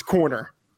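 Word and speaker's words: Koerner: Matthew Friedman is on a mission to make sure that everyone in the world now knows how to pronounce Sean Corner's last name correctly, okay? All right, it's Corner.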